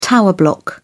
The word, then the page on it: tower block: British English